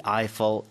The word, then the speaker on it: Eiffel: 아이폴 그러니까 왜냐면 에펠타워라고 yeah. 했었거든요. 근데 The Eiffel Tower라고 하는 게 맞죠.